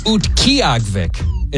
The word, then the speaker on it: Utqiagvik: I think it's pronounced Utqiagvik. It's very hard to read.